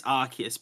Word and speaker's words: Arceus: It is Arceus, by the way. I have heard from people that's how it is supposed to be pronounced,